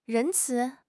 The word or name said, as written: 仁慈